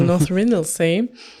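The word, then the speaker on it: North Ronaldsay: Aber auf der schottischen Insel North Rinnelsay, die schreibt sich Ronald Say, aber wie immer in Großbritannien spricht man Dinge sehr seltsam aus. – Also North Rinnelsay.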